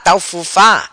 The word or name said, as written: Taufufa